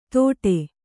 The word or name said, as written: ಥೋಡೆ